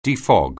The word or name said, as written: defog